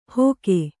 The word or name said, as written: ಹೊಗೆ